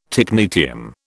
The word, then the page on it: technetium: British English